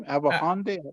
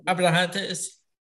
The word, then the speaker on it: Abrahantes: And I always want to see, every time you see a little bit of that Alex, how do you say his name? Abrahante? – Abrahantes.